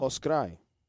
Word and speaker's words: poscrai: Wait, how do you say the day after tomorrow? – Poscrai.